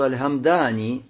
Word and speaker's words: Аль-Хамдани: Дальше одного и указывает, что Аль-Хамдани, надо вот так произносить, Аль-Хамдани, то есть сукуном буквы Мим, Хамдани, и Даль, Мухмаля, Мухмаля, то есть без точки, хорошо? Не Хамдани, а Хамдани, Даль.